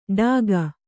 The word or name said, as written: ಡಾಗ